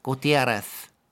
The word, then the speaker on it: Gutiérrez: En slags, det vi kaller lesping. Ja. – Lesping, ja. Gutiérrez.